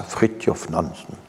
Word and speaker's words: Frithjof Nansen: Euh, c'est la Société des Nations qui crée un statut des réfugiés apatrides en 1921 et Frithjof Nansen, ça se prononce comme ça, Frithjof Nansen, zoologiste, explorateur norvégien.